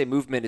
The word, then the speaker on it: MVMT: And when I say Movement, it's spelled out MVMT.